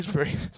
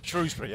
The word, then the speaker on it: Shrewsbury: Because as I understand it, you pronounce it Shrewsbury if you're working class or post-working class. – Shrewsbury, yeah. – You pronounce it Shrewsbury if you're posh. And whatever a stranger says, they're wrong.